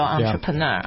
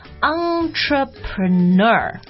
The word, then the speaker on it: entrepreneur: 他这种说，哎呦，那牛得很了，serial entrepreneur、 yeah. – 啊。 – Yeah. – 好，那这我们最后再说一下这个词的发音啊，entrepreneur。 – entrepreneur。 – 对，这发音稍微有一点点难啊，所以 再跟我们讲几遍。 – entrepreneur。 – 所以是 entre，然后 preneur。 – Yeah, it's a French word.